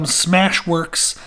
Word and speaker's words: Smash WeRX: It's Smash WeRX. So if you want to check that out on YouTube, S-M-A-S-H, Smash, then W-E-R-X, Smash WeRX. Smash Works is what he says.